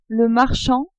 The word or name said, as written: marchand